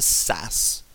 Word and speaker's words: SaaS: Software as a service is a must, abbreviated as SaaS, pronounced as SaaS.